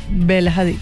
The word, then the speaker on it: Bella Hadid: Bella Hadid. – Bella Hadid, sí.